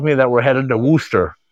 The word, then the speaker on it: Worcester: Worcester. – Worcester. – Worcester.